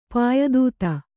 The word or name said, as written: ಪಾಯದೂತ